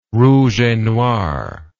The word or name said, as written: rouge et noir